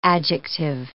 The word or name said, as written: adjective